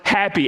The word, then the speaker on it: Hapi: That, that plague is often associated with the goddess Happy, H A P I, pronounced as you and I say the word happy, happy.